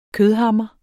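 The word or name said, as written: kødhammer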